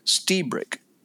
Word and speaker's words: Lstiburek: it's it's spelled L S T I B U R E K, and it's pronounced Stebrick. That's how he pronounces it. Back in the old country, they would actually pronounce the L, but um, the, when they moved over here, they dropped the L sound, I guess.